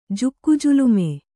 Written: ಜುಕ್ಕಜುಲುಮೆ

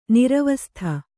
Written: ನಿರ್ವಾಸಿತ

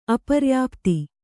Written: ಅಪರ್ಯಾಪ್ತ

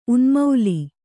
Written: ಉನ್ಮಾಳ

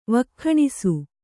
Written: ವಕ್ಖಾಣಿಸು

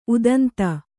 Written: ಉದಂತ

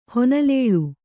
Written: ಹೊನಲೇಳು

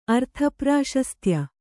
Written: ಅರ್ಥಪ್ರಾಶಸ್ತ್ಯ

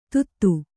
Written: ತುತ್ತು

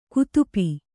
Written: ಕುತಾಪಿ

